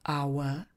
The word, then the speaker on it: hour: H souhlásku v tomhle případě nevyslovujeme. Výslovnost začíná samohláskou. A. Our. Proto použijeme neurčitý člen ve tvaru an.